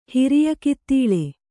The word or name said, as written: ಹಿರಿಯಕಿತ್ತೀಳೆ